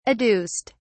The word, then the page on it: adduced: British English